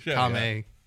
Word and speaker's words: Kamehameha: with its little Kamehameha. – That's how they say it in the show. That was really good. Kamehameha.